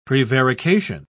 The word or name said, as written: prevarication